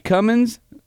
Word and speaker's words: Cummins: I currently own a 03 Dodge Cummins, hashtag the G is silent, or as we like to say, the Gizzlin, 86 CJ7, and a 98 F-150 that doesn't currently run.